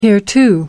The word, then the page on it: hereto: American English